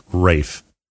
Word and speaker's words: Ralph: It's not Ralph Fiennes. – It is Ralph Fiennes. – No, it's Ralph. – No, Fiennes. It's Ralph Fiennes. It's pronounced Ralph Fiennes. – Really? – Yes, his first name is pronounced Ralph. – Ralph. Oh, okay, so it sounded like you were saying Ray.